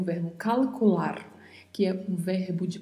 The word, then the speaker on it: calcular: E temos ao final um R com vibrato. Não se esqueça deste vibrato, treine ele porque possivelmente é uma das coisas mais difíceis de se aprender no espanhol. Então pronunciamos calcular.